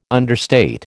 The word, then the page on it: understate: American English